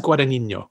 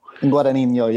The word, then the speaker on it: Guadagnino: I think it's Guadagnino. – Guadagnino, yeah, Guadagnino. Exactly.